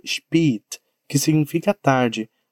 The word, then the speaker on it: spät: A pronúncia é spät. Spät. Com o som de E mesmo. Em via de regra, não estaria errado se você pronunciasse com o som de E. Spät. Spät. Porém, é menos comum.